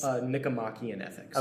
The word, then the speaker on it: Nicomachean Ethics: Nicomachean Ethics.